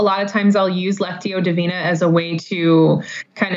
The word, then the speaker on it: Lectio Divina: the Lectio Divina. I'm not sure if you pronounce it that way. – Yep.